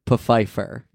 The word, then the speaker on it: Pfeiffer: It's the misadventures of Abraham Lincoln, his loony associates, and the only sane man among them, the president's black butler, Desmond Pfeiffer. – Yeah. – It is pronounced Pfeiffer. – Yeah. – It's spelled like Pfeiffer, like Michelle Pfeiffer.